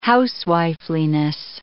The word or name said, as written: housewifeliness